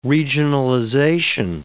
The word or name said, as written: regionalization